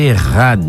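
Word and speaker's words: errad (אחד): O termo, inclusive, na pronúncia do hebraico para a palavra unidade é errad, né? Errad, a pronúncia.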